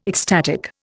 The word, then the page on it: ecstatic: Indian